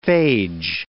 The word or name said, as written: phage